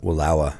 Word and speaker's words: Wallowa: As Roy and I entered the unforgiving sanctuary of the Eagle Cap Wilderness for the first time and witnessed the awe inspiring Wallowa, how do you say that? Wallowa.